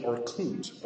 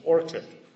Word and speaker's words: Orkut: I always want to pronounce it Orkut, but I'm told the pronunciation is Orkut.